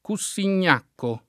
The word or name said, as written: Cussignacco